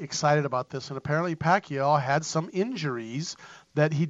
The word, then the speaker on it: Pacquiao: Pacquiao fight Manny Pacquiao.